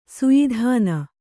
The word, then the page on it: ಸಯದಾನ: sayadāna